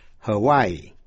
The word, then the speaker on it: Hawaii: Tiểu bang Hawaii, Hawaii phát âm là ba syllables, Hawaii. Tiểu bang Ohio, nhấn mạnh vào vần nhì, nhưng Idaho, I-D-A-H-O, nhấn mạnh vào vần đầu, Ohio, Ohio, Idaho.